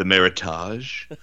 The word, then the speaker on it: Meritage: So what's the correct pronunciation then? – Meritage. – Meritage. All right.